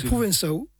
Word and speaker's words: provençal: Et c'est ça, je prononce bien là ou provençau. – Provençau. – Provençal, puisqu'en provençal, on vocalise le L final qui devient AU, AU.